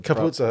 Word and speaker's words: Capuzzo: I think, is the Capuzzo, that's right. That's the correct pronunciation.